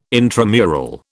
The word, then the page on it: intramural: British English